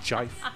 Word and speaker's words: gif: That would make a terrible gif. Or is it gif? – Gif. – It's a gif. – It's pronounced jife. – Jife.